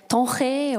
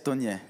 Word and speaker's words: Tonje: So uh, that's how you pronounce my name, not uh, Tonge or Tonge. Et c'est comme ça qu'on dit mon prénom, c'est pas euh, Tonge ou Tonge, c'est Tonje.